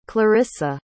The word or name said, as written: clarrisa